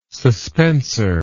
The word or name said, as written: suspensor